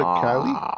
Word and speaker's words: Calle: It's Sasha Calle. – Banks. Well, they call it Callie, but it's spelled Calle.